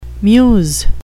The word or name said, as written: muse